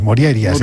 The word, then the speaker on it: Morjaria: Morjaria, sí. Es un apellido... ¿De dónde será esta señora? – Será rumana o algo así, ¿no? ¿No crees? – Bueno, la J normalmente se pronuncia como I. Moriaria sería.